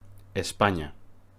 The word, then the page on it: España: Spanish